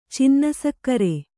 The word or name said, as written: ಚಿನ್ನಸಕ್ಕರೆ